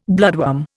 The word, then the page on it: bloodworm: Indian